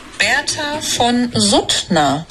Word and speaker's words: Bertha von Suttner: Bertha Van Sutter. – Aspetta, aspetta. – L'hai cercato, come si dice? Vediamo. Sentiamo. – Bertha von Suttner.